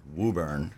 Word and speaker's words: Woburn: My GPS on the way down there pronounced it Woburn, and apparently that's how you're supposed to pronounce it.